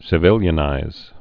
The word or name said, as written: civilianization